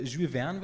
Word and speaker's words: Jules Verne: Ursprünglich Captain Nemo natürlich ein Jules, äh, Jules Verne, wird es glaube ich aussprechen, Charakter. – Ja, ist also richtig.